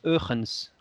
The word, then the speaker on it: Huygens: Huygens, avec un Y là, c'est ça H-U-Y-G-E-N-S, e ouais. Huygens. Alors en hollandais, euh, j'ai vérifié, ça se prononce Eugens, rien à voir.